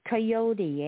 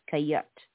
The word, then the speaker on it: coyote: It's coyote, eh, in English? Because in French it's coyote. But in for English, it's coyote.